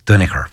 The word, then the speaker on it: Duinnachair: Kafusun, because my father used to pronounce it, it was spelled D-U-I-N-N-A-C-H-A-I-R, which is Dunicker, which is Danoghue.